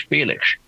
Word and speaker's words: Felix: It's not Felix. It's Felix.